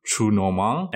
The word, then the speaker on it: Trou Normand: All right, so tonight we'll be discussing episodes 9 and 10 of Hannibal's first season, titled Trou Normand and Bouffe Froid.